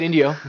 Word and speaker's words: Indio: Indio. Did I say India? – No, you said Indio. You said it right.